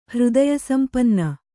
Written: ಹೃದಯಸಂಪನ್ನೆ

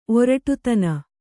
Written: ಒರಟುತನ

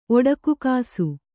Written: ಒಡಕುಕಾಸು

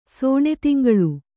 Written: ಸೋಣೆತಿಂಗಳು

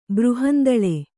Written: ಬೃಹಂದಳೆ

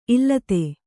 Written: ಈಲಿತ

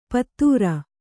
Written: ಪತ್ತೂರ

